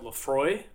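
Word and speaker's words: Laphroaig: I bought a bottle of something called Lafroy. – Okay. – But it's spelled like P-H.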